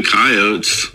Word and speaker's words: coyote: First off, pronouncing it coyote is kind of like saying Missouri. I can tell where you're from. – Oh, okay. Yeah. – That's like a southwestern thing.